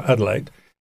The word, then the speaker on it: Adelaide: Mm, y, Adelaide, y, tak to się wymawia, Adelaide,